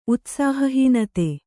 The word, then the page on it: ಉತ್ಸಾಹಹೀನತೆ: Kannada